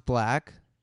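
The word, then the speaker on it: 6lack: Bro, he dropped merch that said it's pronounced black.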